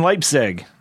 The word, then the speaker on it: Leipzig: Now, you were born in Leipzig.